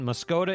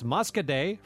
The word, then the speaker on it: Muscoda: John says, for those in the know, it isn't Muscoda, it's Muscaday.